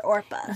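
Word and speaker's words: Orpah: Wait, but, so they meant to call her Orpa? – Yes. – Okay. – But after people mispronounced her name her whole life, she was like, you know what? Everyone just keeps calling me Oprah, so we're just going to go with that.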